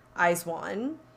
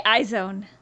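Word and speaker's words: IZ*ONE: Is that how you say it? – That's how I'm saying it. – I say iZone. – Oh, I think it's Eyes One.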